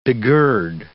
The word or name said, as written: begird